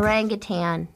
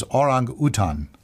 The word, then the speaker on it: orangutan: There's no G at the end of orangutan. – I didn't say it. – Oh, okay. Good. – I might have. Okay. Orangutan. – Mm-hmm. Or its actual original pronunciation is orangutan.